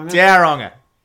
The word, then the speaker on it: Tauranga: Oh, well, I've actually got a mate who lives up in Tauranga. – Tauranga.